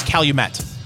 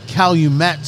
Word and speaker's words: Calumet: I thought it was Calumet. Calumet, not Calumet? We'll call it Calumet then, Fingers Malloy.